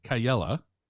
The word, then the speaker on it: Kaiyala: My guest today is Kris Kaiyala.